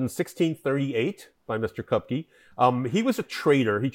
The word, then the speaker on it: Kopke: So first, I'd love to hear more about Kopke. Or Kopke. I'm mispronouncing it, right? – Kopke. Kopke. They in Portugal they say Kopke, but in the States we just say Kopke.